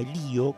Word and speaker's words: Elío: Entonces se enfrenta al virrey del Río de la Plata, que en ese entonces era Francisco Javier de Helio, o Elío, con acento en la I,